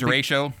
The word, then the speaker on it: Derecho: Am I saying that right? – The Derecho.